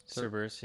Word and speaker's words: Cerberus: Cerberus, yeah.